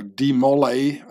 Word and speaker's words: de Molay: Jacques de Molay, or de Molay, as Americans say it,